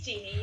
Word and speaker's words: Jeannie: It is Jeannie, yes.